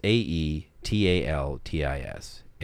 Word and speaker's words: Aetaltis: Ataltus, Ataltus, I've never heard this spoken. It's A E T A L T I S. Aetaltis.